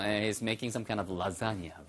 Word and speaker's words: lasagna: To your lasagna. Lasagna. L-A-S-A-G-N-A에서 발음은요. 라자니아처럼 발음돼요.